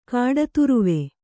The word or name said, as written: ಕಾಡತುರುವೆ